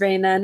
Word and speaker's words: Reina: Is that how I pronounce your name? Am I pronouncing it correctly, Reina? – Yes, you are pronouncing it right. It's Reina.